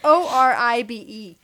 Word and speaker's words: Oribe: Yeah, he's a hairstylist from, believe it or not, back in the 80s, and he's made himself this big deal, and I believe it's Oribe is the Oribe.